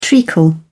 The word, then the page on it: treacle: British English